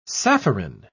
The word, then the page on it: sapphirine: British English